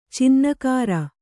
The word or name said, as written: ಚಿನ್ನಕಾರ